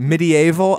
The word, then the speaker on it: medieval: For people who don't know, the summoner, well, a summoner in medieval society, medieval. – Medieval. – I love when British historians say it in three syllables.